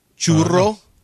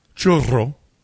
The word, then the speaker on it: churro: What word do you roll your R on? – If it's double R. – So carro. Churro. – Churro.